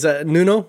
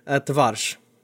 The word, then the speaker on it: Nuno Tavares: Would you give us the correct pronunciation of this man's name, who I'm not going to say again? – Yes, no worries. Nuno. Nuno's easy, right? Tavares. – Tavares. Okay, so, cool. – Tavares. Perfect. That's what if you're doing it, maybe.